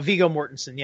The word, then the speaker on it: Viggo Mortensen: I like Viggo Mortensen, if I'm pronouncing his name right. – Just about Viggo Mortensen, yeah.